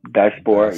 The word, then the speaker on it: diaspora: How do you say diaspora? – Diaspora. – Diaspora. Yeah.